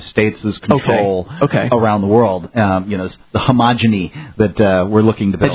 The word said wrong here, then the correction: hegemony: Hegemony? I think it's hegemony.